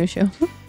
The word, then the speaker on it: Mushu: Is that how you say it? – Yeah, right? Mushu.